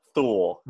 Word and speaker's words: Thule: And it looks like it rhymes with mule, Thule.